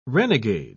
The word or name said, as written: renegade